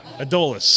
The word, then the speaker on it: Adolis: Adolis? – Adolis? – Yeah. – Adolis.